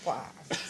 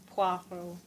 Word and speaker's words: Poirot: It's Hercule Poirot. – Something. – Poirot. – So it's a poir sound. Poirot. All